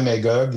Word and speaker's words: Magog: regarde, tu vois, je le prononce comme les gens de la place. Euh, euh, ma mère était, Colette Biron, était enseignante au primaire. Mon père était policier à Magog et on était cinq frères.